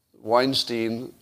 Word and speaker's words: Weinstein: I got $5 of advice to remember that Weinstein rhymes with Einstein.